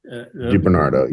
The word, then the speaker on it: DiBernardo: And of course Vanessa DiBernardino. – DiBernardo. Yeah. – DiBernardo. Thank you.